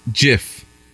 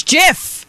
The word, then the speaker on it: GIF: The inventor of the widely used web illustration declared it should be pronounced GIF, like the brand of peanut butter, rather than with a hard G sound. So it is GIF. GIF like the peanut butter.